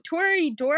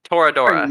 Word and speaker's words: Toradora: Tori Doro? Am I saying that right? – Toradora.